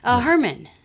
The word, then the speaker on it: Herman: I always wondered, it's Herman or her? – Herman. – Herman, yeah. That's how it's spelled.